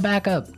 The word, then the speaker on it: backup: I think, though, it's important to point out that here, the pronunciation and stress of this phrase is really important, mm-hmm. Hey? – Yes, yes. – You have to say backup.